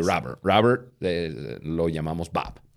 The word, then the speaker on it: Bob: Ahora, em, Bob, no Boob.